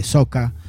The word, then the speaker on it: Soukous: empezaba a formar parte de la escena del soca, la rumba congoleña sería, se escribe Soukous, si lo quieren buscar, Soukous con K, se lee soca, es la música de Centro África, ¿no?